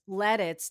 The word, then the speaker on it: Lititz: I are here today to talk about Lititz, Pennsylvania. – No, I thought you said let it. Lititz. It's Lititz, not Lititz. Or it is Lititz. – Lititz. Yeah.